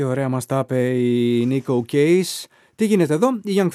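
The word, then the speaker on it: Neko Case: Αυτό είναι το Destination, που μόλις παραθέσαμε δεύτερο στην πεζολίστα μας, με την Nico Case. Λέγεται Nico, αλλά προφέρεται, γράφεται N-E-K-O.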